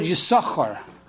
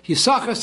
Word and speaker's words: Yisachar: Now Yisachar is spelled Yud, Shin, and a second Shin. But the second Shin is silent, we do not pronounce it.